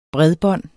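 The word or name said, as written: bredbånd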